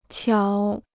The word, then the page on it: 巧: qiǎo